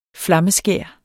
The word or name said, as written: flammeskær